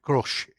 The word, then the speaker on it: Grusch: I think Grusch, Grusch is how it's pronounced.